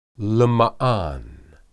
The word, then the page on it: lemaan: Hebrew